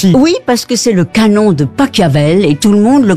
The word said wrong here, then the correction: Pachelbel: Mais non, c'est de Pachelbel.